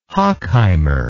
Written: hochheimer

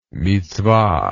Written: mitsvah

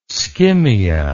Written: skimmia

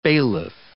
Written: bailiff

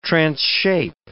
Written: transshape

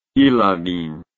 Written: ilorin

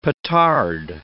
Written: petard

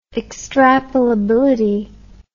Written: extrapolability

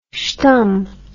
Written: schtum

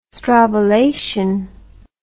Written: strobilation

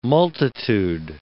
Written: multitude

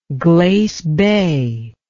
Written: glace bay

